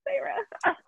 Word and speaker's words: Feyre: I had to look up how to pronounce her name. I think I either asked, like, Katie or something. I was like, how do you say her name? I don't even remember how I pronounced it beforehand. And I was like, oh, it's Sarah.